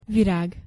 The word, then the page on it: virag: Hungarian